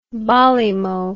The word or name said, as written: barley mow